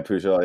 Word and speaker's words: Pujol: I hadn't heard of, I have heard of Hotel de Ville, but I've not heard of Pujol, if I've pronounced that correctly. – Yeah, Pujol, yeah.